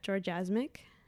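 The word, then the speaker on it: Jorja Smic: You can follow me on Instagram or Twitter at Georgiasmic. My name is spelled J-O-R-J-A and then S-M-I-C.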